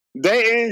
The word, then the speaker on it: Dayton: She's from Dayton. – You know what I'm saying? Dayton. But like, Dayton, you can't say the T.